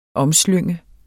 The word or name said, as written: omslynge